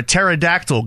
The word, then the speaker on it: pterodactyl: Why can't you hear a pterodactyl go to the bathroom? – Why? – Because the P is silent.